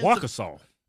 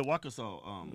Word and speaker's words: Waukesha: Like that one guy up in Waukesha. Is that how you pronounce it? Michigan? That ran that Christmas parade? – Yeah, yeah. – This dude has an extensive... – Waukesha. – Yeah, Waukesha.